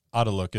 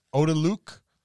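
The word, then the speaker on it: Otiluke: I always wonder if I'm pronouncing Adeluk. Is it Odaluk? – I think it's Adeluk's or Adeluk. – Adeluk? – Adeluk. I think so, too.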